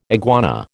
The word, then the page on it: iguana: American English